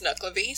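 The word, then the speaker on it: Nuckelavee: But yeah, I've seen it pronounced a few ways, but the most consistent was Nuklavi, so that's how I'm pronouncing it.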